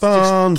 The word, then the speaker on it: Barnsley: No, that's not how you say it, lads. I'm not from Barnsley. – The greatest team the world's ever seen, and it's Barnsley. – You've added a R in there, lads. And it doesn't.